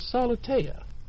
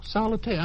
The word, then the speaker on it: Solitaire: Oh, they're playing a song that's called Solitaire, you see. – Solitaire? – Yes. Mm-hmm. Oh, Solitaire. I'm sorry, I pronounced it wrongly.